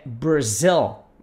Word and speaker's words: Brazil: Se você quiser falar Brasil, lembra que a pronúncia é Brazil. Brazil. Agora, se você quer dizer eu moro na cidade tal ou no bairro tal, I live in.